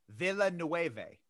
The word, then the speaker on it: Villeneuve: It's Denis Villeneuve. – So it's actually Denis. – Well, it's Denis, like the S is kind of silent, but yeah. – I always thought it was Denis Villeneuve.